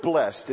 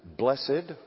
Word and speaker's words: blessed: Do you pronounce it blessed or blessed? Is it blessed or blessed? It's blessed when it is descriptive of the one who gives a blessing.